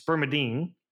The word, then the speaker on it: spermidine: And so you show up, spermidine, and by the way, I apologize, I think I was mispronouncing it earlier, spermidine. So it's spermidine.